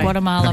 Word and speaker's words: Guatemala: Is it Guatemala? Is it, how do you say it? – Guatemala.